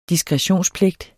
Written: diskretionspligt

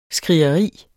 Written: skrigeri

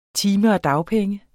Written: dagpenge

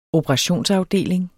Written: operationsafdeling

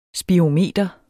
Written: spirometer

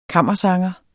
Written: kammersanger

